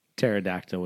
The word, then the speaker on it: pterodactyl: Because the P is silent. Because you spell pterodactyl with a P and it's silent.